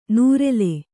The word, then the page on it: ನೂರೆಲೆ: nūrele